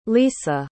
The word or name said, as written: lyssa